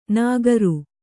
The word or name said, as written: ನಗರು